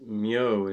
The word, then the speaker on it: Myo: And that's actually pronounced Myo in Japanese, and it's very common in Dharma names.